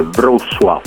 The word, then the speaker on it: Wrocław: Dalla Danimarca al centro Europa per visitare la città polacca di Wrocław, pronuncia difficile, Wrocław.